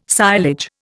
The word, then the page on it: silage: Indian